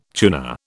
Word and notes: tuner: British English

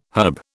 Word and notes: herb: British English

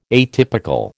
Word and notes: atypical: American English

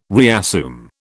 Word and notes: reassume: British English

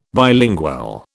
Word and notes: bilingual: British English

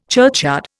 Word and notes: churchyard: Indian

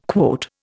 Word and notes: quote: Indian

